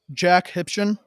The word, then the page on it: Jack Hipchen: male